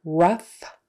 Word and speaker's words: rough: So in the words rough, tough, tougher, roughage, and enough, those are all pronounced uff, even though it's spelled O-U-G-H, and which looks nothing like uff. You would think it would be spelled U-F-F or U-F or something like that, but no, it is spelled O-U-G-H. Don't ask me why. So you just have to memorize them. So remember, rough, tough, tougher, roughage, enough. Those are all pronounced uff.